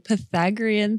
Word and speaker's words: Pythagorean: Pythagorean theorem? – My answer is also Pythagorean theorem.